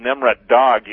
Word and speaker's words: Nemrut Dagh: and in a place that's in Turkey called Nimrut Dag, you have, and that's pronounced or spelled N-E-M-R-U-T-D-A-G-H, you have seven giant thrones that sit overlooking the plains in southeastern Turkey.